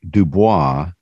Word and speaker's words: Dubois: Your book is Subversive Witness, Scripture's Call to Leverage Privilege, Dominique Dubois Gilliard, G-I-L-L-I-A-R-D, right? – Yeah, I go with the U.S. Pronunciation of Du Bois because I'm but that's the right name because all three of my names are French, and so that would have been the right global pronunciation, but I'm named after W.E.B.